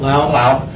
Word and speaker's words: Lau: I had a short conversation this last week with Dan Lowe, or Lau. How do we pronounce Dan's last name? – Lau.